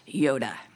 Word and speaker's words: Joda: son of Joda, or I think with a good Hebrew pronunciation, Yoda.